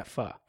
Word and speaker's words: pho: And that's how it's pronounced, right? – Yeah, pho.